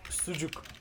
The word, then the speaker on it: sucuk: S-U-C-U-K. – What? – S-U-C-U-K. – Let me send it to you. – Can you pronounce that one more time as well? – Sujuk. – Oh, sujuk.